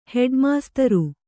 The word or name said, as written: ಹೆಡ್ಮಾಸ್ತರು